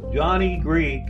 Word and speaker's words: JohnEgreek: Most everyone butchers it until I teach them how to break it down into three syllables. JohnEgreek is a much more interesting name.